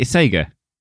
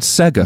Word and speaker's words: Sega: Say Sega. – You didn't say that. You said Sega. – Yeah, I misspoke because I'm ill.